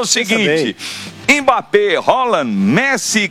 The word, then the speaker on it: Haaland: Mbappé, Haaland, que não é Haaland, viu? Ele ensinou. Foi ele que ensinou. Ele falou: a pronúncia do meu nome é Haaland. Haaland, Messi, Cristiano Ronaldo. Ele falou que é Haaland.